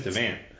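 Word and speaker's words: Davante: And I don't pronounce the E, it's Devant.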